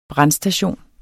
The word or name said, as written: brandstation